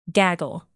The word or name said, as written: gaggle